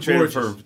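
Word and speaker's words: Bourjos: Peter Borjos.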